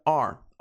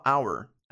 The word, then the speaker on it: our: Lembrando, pronúncia are. Are, que nem o verbo to be, are, are, tá? Você não precisa falar our, our, our, are, are, are, uma forma rápida de falar.